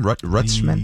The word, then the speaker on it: Rutschman: Adley Rutschman, is that how you pronounce his name? Rutschman? – Yes.